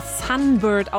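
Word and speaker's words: Sūnbyrd: Denn sie wird zwar Sunbird ausgesprochen, aber nicht so geschrieben. Das U hat einen waagrechten Strich oben drüber. Und Bird wird mit Y geschrieben.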